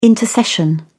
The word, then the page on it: intercession: British English